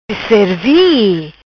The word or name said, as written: Cerzi